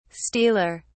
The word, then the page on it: stealer: British English